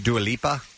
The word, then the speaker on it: Dua Lipa: There's a Dua Lipa.